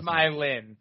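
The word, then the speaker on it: Smile'n: Smile in.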